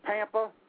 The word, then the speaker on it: Pampa: Am I pronouncing this right when I say Papa? – No, it's Pampa. – Pampa? – Oh, like okay. – Tampa.